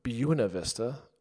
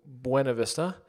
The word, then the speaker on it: Buena Vista: This year, we decided to have a base camp in Buena Vista, or as some people say, Buena Vista. I don't know why they pronounce it that way, but I think it's Buena Vista.